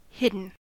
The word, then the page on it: hidden: American English